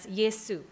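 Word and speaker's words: Yesu (Ἰησοῦς): And in Greek, it is pronounced as Yesu.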